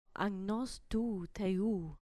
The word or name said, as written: amnos tu theu